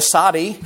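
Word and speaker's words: Sadi (צדי): And Dr. Galeotti, my Hebrew professor, said that the Sadi, the Hebrew letter Sadi, he said the best way to pronounce it is to imitate a camel belching.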